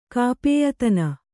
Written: ಕಾಪೇಯತನ

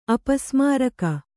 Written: ಅಪಸ್ಮಾರಕ

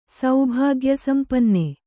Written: ಸೌಭಾಗ್ಯಸಂಪನ್ನೆ

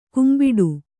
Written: ಕೆಂಬಟ್ಟೆ

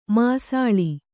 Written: ಮಾಸಾಳು

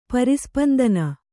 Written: ಪರಿಸ್ಪಂದನ

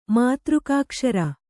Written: ಮಾತೃಕಾಕ್ಷರ

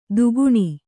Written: ತಗಣ

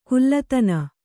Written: ಕುಲ್ಲತನ

